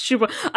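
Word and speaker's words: Csupó: What you probably don't know is that the creator of the show, Gabor Casupo, I'm sure that's the real name, so I feel bad. it is his name. I think it's pronounced Shupo.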